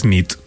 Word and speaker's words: nit: Y noche es nit. – Muy bien. – Bona nit. – Bona nit, es verdad. – Bona nit.